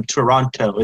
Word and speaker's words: Toronto: I'm from Toronto. It's like, no, you don't say the last T or they're going to know.